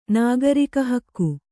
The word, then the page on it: ನಾಗರಿಕಹಕ್ಕು: nāgarika hakku